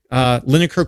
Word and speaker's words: Linacre: So you can go and Google Lineker Quarterly, that's spelled L-I-N-A-C-R-E Quarterly, and see what we have been up to.